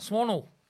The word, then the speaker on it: Swan Hill: No, but left South Australia, moved to country Victoria, and I arrived in Swan Hill, well, just outside Swan Hill. – No, I think you're pronouncing that wrong. – Swannel. – Swannel.